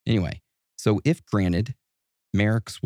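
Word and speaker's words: Marax: It looks like Merax, but they say it's pronounced Merix.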